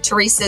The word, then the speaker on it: Teresa: I will tell you, Teresa is spelt without an H, just T-E-R-E-S-A.